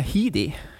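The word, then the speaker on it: Headdy: Hvordan uttaler du det? Heady. Ja.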